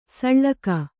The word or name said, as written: ಸೂಳ್ಗೈ